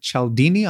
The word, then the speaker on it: Cialdini: From what I understand, this concept of psychological triggers is based on the work of Robert Cialdini.